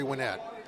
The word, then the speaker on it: Winnett: or just on Facebook under Jerry Winnett. – Cool. Spell that last name for us. – W-I-N-N-E-T-T.